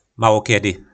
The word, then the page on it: malokedy: po našimu